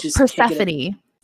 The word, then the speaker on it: Persephone: Oh, Persephone.